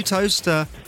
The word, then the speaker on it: Towcester: The place that I think Chris pronounced it as Towcester once. – That's right, yes. – But it's easy to do because it looks like Towcester, but it's actually Toaster.